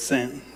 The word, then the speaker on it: saint: Well, in French, saint, saint, saint. And of course, you don't say it like that. It's saint.